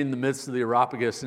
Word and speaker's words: Areopagus: I'm reminded of Paul in Acts 17 when he was at the Areopagus. I just butchered that. I'm not even going to try again. It's not in here today. I had it earlier this week. Areopagus, there we go.